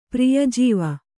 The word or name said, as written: ಪ್ರಿಯಜೀವ